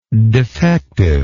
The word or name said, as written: defectiveness